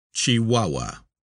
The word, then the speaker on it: Chihuahua: Footnote. Pronounced Chihuahua.